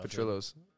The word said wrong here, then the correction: Portillo's: or Petrillo's. – Portillo's. – Portillo's.